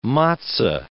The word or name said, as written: matzo